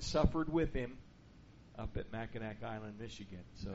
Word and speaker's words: Mackinac: just in Michigan suffering at Mackinac Island at the Grand Resort,